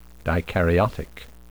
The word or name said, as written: dikaryotic